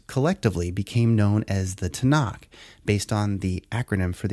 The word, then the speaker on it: Tanakh: And together, the T-N-K is pronounced Tanakh for simplification.